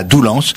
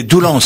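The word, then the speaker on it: Doulens: Alors c'est Doulance, ça s'écrit, mais on dit, on prononce, paraît-il, là-bas. – Doulan. – Doulan. – Ah ouais, d'accord. – Sinon, ils te chopent, ils te disent, c'est pas ça.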